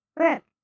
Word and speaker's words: Bruce: I still don't know how you get B-R-U-C-E into Brooke. – That's easy. The C sounds like a K. Brooke.